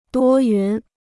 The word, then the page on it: 多云: duō yún